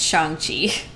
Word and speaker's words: Shang-Chi: You're going to hate me, but it's Shang-Chi. Not Shang-Chi.